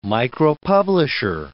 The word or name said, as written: micropublisher